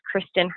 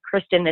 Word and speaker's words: Krystin: My name is Kristin Hernandez, but Kristin is spelled K-R-Y-S-T-I-N.